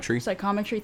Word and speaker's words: psychometry: Psychometry, thank you.